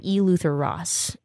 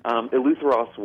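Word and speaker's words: Eleutheros: In chapter 3, you talk about E. Luther Ross and let's talk about what that's all about. – Sure. And you're good. You pronounced that very well. Eleuther Ross was the local Orlando-based ministry within Exodus.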